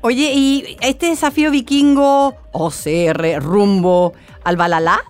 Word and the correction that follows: Valhalla: Rumbo al, rumbo Valhalla, exactamente. Al, Valhalla. Oye, el Valhalla, ¿cómo se dice? ¿Bajala o bajala? Valhalla.